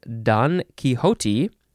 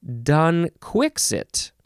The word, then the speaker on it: Don Quixote: The one way is Don Quixote, or Don, even though there's no G there. I've also heard Don Quixote, and then the chiefly British way is Don Quixit. Because it is spelled D-O-N, capital D-O-N, next word, capital Q-U-I-X-O-T-E. I would never say Don Quixit, and I did not realize the British often say that.